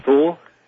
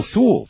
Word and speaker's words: Thule: The Who Society? – Thule. – How do you spell that? – I want to say, T-H-U-L-E. – Oh, Thule. Thule.